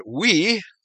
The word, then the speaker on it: oui: As in the French word oui, O-U-I, which consists of three vowels, but is pronounced the same as we, W-E, in English. In a similar manner, when transliterated into Greek, Y-H-W-H can be written and pronounced I-A-O-U-E, Yahweh.